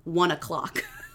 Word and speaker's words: ONE OK ROCK: So he is in a Japanese rock band that is huge called One OK Rock, but it's pronounced 1 o'clock.